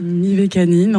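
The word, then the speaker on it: Mivekannin: Mivakanin ou Mivakanim? – Mivakanin, en tout cas, c'est comme ça Mivakanin. Que moi je le prononce.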